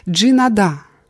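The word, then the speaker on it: Джинада (加拿大): У меня всегда был хороший аппетит, и как единственный канадец за столом я вскоре получил прозвище «Джинада». Канада на мандаринском наречии с ударением на «нада», что значит «прийти и взять большой кусок».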